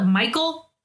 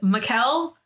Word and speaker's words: Michel: And it's not Michael. So we're not going to do that. So we're going to go with Michel. But I think it's Michel more if you actually pronounce it correctly, because that's an I, not an A.